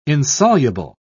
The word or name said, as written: insoluble